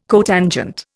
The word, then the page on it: cotangent: Indian